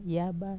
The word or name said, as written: yaba